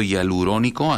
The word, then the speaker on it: hialurónico: la aplicación o los beneficios del ácido hialurónico, así se pronuncia, ¿verdad? – Sí, correcto.